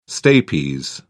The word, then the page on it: stapes: British English